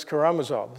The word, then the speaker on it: Karamazov: I don't know if you ever read Dostoevsky's Brothers Karamazov.